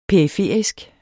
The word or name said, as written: periferisk